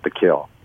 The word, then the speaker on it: Kiln: It's spelled K-I-L-N, but everyone there calls it Pekin, Mississippi.